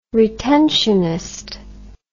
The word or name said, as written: retentionist